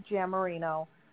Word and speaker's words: Giammarino: So do you want me to just put Linda GM, is it GM Marino? Did I say that right? – Yeah, Linda Giammarino, R-E, big letters, R-E, mm-hmm.